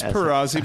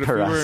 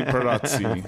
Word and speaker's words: Pirozzi: It's Perazzi, but Perazzi. if we were in Rome, it would be Perazzi.